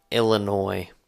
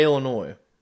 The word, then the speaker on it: Illinois: Why Illinois? – It's because I hate Illinois. – Why do you hate Illinois? – Because they have an S at the end of their name that you don't even pronounce.